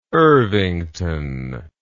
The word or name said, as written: irvington